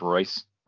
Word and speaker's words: Reus: Isn't it R-E-U-S? Okay. – Correct, but yeah, way to pronounce it is Royce.